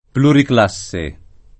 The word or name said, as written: pluriclasse